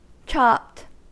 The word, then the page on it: chopped: American English